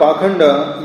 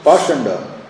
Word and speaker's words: pakhanda: The word pakhanda used in this verse is sometimes pronounced pashanda.